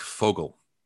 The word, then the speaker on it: Vogel: I found out that the V's are F's. Yeah. So you know, Frank Vogel. Vogel means bird in German. His name would be Frank Vogel. – Oh shit! – That's how you say it.